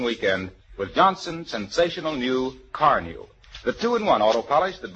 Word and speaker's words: Carnu: Remember the name, Carnew. It's spelled C-A-R-N-U, and it's made by the makers of Johnson's Wax.